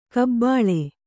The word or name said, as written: ಕಬ್ಬುಲಿ